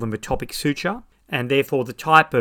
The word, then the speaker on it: metopic: Another type of craniosynostosis, which is the second most common type of single suture craniosynostosis, is called metopic craniosynostosis, although some surgeons pronounce it metopic craniosynostosis.